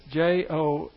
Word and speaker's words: Joaquin: I'm not sure how to pronounce this. Honaquin, I believe, is how you would pronounce it. It's Joaquin.